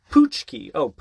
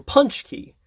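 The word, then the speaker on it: pączki: Immigrants of Poland pronounce them poochki, or punchki, or even punchket.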